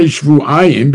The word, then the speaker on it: shvu'ayim: So according to you, ahani kra, the pasuk as it's read, meaning as it's pronounced, is shvuayim.